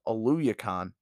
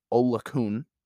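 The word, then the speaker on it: Oluokun: I always have been pronouncing it as Oluyakon. Found out that that's not how you pronounce it. It's supposed to be Olukun.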